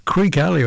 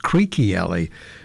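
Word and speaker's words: Creeque Alley: The girl from Adelaide, Bev Harrell, What Am I Doing Here With You, and Creek Alley. In fact, it's supposed to be pronounced Creaky Alley.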